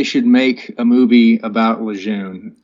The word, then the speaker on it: Lejeune: He was Major General John A. Lejeune, or pronounced Lejeune, I guess, if you're a Marine Corps purist.